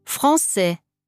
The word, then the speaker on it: français: Aber C mit dem kleinen Häkchen wird vor A, O und U als scharfes S ausgesprochen. – Ça va, garçon, français.